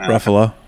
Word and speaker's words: Ruffalo: Yep, Ruffalo.